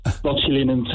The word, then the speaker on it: botulinum: Uh, botulinum toxin.